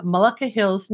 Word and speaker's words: Mullica Hill: Jennifer is a longtime Slow Flowers member, a floral designer, and based in Mullica Hills, New Jersey.